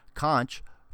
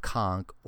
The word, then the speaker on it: conch: Conch, pronounced conch or conch, is prized for its delicious meat, which is eaten raw in salads or cooked as in fritters, chowders, gumbos, and burgers.